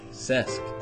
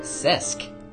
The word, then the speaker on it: Cesc: that means the first name is Cesc. – Cesc.